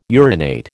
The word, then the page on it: urinate: American English